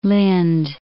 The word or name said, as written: Lynd